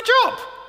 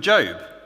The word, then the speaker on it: Job: No, this is the Book of Job. – It is? – Yeah. – But it's spelt Job. – Yeah, I know it's spelt Job, but you say Job.